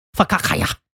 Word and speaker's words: focaccia: It's going to have salami, rosemary ham, provolone cheese, sun-dried tomato spread on toasted focaccia. That's how you say it, actually.